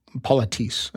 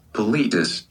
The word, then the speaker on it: politesse: Politis. – Politis. – Why does yours have such a robot voice? – That's YouTube. – Oh. – Yeah. – It rhymes with elitist.